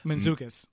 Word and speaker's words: Mantzoukas: Jason. – Mantzoukas. – Mantzoukas, is that how you pronounce it? – Yep.